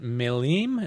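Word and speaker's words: Maleme: was based at Melim. Is that how we say it? – That's how you say it.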